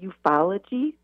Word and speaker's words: ufology: Is it ufology? How do you say that? – You got it right.